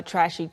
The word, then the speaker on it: trash: 발음을 주의해 주세요. 쓰레기 자체는 trash. – trash. – 그냥 shh. 모음이 trash. – 마지막에 모음이 없어요.